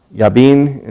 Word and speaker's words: Jabin: God sold them into the hand of Jabin, king of Canaan, Yabin, and pronounced in Hebrew, who reigned up in Hazor or Hatzor.